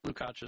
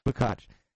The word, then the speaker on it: Lukacs: And that is John Lukacs. Did I say that right or I screw it up? Lukacs. – Lukacs is fine. – Lukacs.